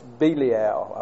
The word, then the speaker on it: Belial: It's Belial, I believe is the way you pronounce it.